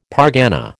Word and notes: pargana: American English

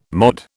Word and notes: MOD: British English

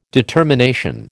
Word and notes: determination: American English

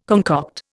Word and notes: concoct: Indian

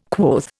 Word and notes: quoth: Indian

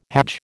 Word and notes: hajj: American English

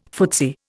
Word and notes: footsie: Indian

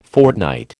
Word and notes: fortnight: American English

